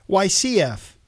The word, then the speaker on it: YCF: YZF, they're brand new. Check them out. – YCF. – YC, like an ABC, yep.